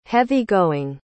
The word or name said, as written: heavy-going